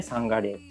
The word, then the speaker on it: 山河令: 日本語読みでサンガレーって読んでるんですけど